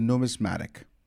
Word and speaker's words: numismatic: Numismatic.